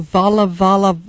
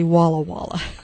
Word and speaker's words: Walla Walla: because in Hawaii the W's are pronounced as V's, and so he assumed that we had that pronunciation too. But the town was really Walla Walla.